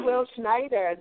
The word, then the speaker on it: Will Schneider: Well, welcome, Mr. Will Schneider. Am I pronouncing your name correctly, Will? – Yeah, hi. Yeah, that's how I pronounce it.